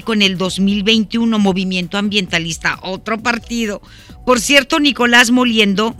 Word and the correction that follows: Mollinedo: Monile, Moliendo, o Mollinedo, es Mollinedo, perdóneme usted, es Mollinedo Nicolás Mollinedo. Lo dije mal.